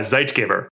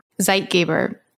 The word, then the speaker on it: Zeitgeber: So how do you say it? And I should know this because I'm German. – Oh, you are German. Okay. So that's a German word. Yeah. Zeitgeber. – Zeitgeber.